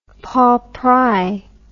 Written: Paul Pry

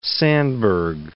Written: Sandburg

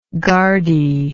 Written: guardee

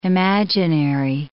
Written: imaginary

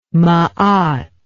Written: maat

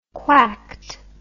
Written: quacked

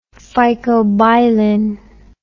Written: phycobilin

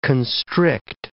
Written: constrict

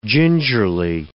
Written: gingerly

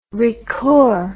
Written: ricoeur

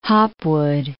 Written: Hopwood

